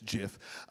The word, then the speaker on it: GIF: You guys say GIF, I think it's JIF. Okay, anyway, I don't know.